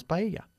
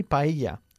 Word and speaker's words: paella: By the way, if you say paella to an English person or an American, they probably won't know what you're talking about because we say paella. Okay? That's the way we pronounce it.